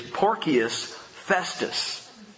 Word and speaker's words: Porcius Festus: I pronounced it correctly. It is Porcius Festus.